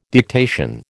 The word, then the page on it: dictation: American English